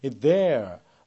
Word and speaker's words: there: There 이 R 발음이 들리는 게 미국식이라면 there 해서 R이 거의 죽어서 들리지 않는 거, 그게 바로 영국식 발음이라고 보시면 되겠습니다. I got one of their business cards 또는 cards 이렇게 나오는데요.